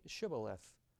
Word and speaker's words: Shibboleth: When he said, 'No,' they said to him, 'Say Shibboleth.' And he said, 'Sibboleth,' for he could not pronounce it right.